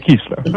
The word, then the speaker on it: Kiesler: Well, okay, I'm pronouncing it as in the German Austrian name, Hedwig Kiesler.